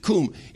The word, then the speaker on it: koum: In the Aramaic, there's a word there that says kum, K-O-U-M. We pronounce it kum.